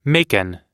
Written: Macon

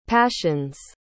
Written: passions

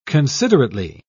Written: considerately

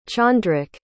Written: chandrak

